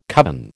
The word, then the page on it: covin: American English